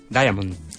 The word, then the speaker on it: diamond: Diamond. – Yeah, and we can say this with two syllables.